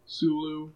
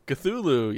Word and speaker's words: Cthulhu: How would you spell it? – C-T-H Sulu. – Cthulhu? – I don't know. – I mean, Cthulhu, yeah. I mean, you spelt it right. But you said it wrong.